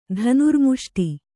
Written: ಧನುರ್ಮುಷ್ಟಿ

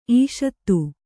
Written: ಇಷುಧಿ

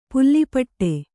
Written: ಪುಲಿಪಟು

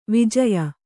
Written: ವಿಜಯ

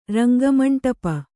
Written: ರಂಗಮಂಡಪ

